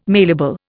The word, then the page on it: mailable: Indian